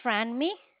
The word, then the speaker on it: Franmi: My name is Franmi. – Can you spell that, please? – It's F as in Frank, R-A-N-M-I. – F-R-A.